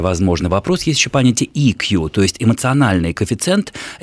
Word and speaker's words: EQ: Только я дополню, что EQ – это если произносить, правильно называя буквы английского языка. Под буквой «и» подразумевается та буква, которая по-русски пишется как «е» – «emotional».